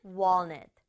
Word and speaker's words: walnut: 근데 영어는 L 소리를 살려서 walnut, walnut 이렇게 발음해 주세요.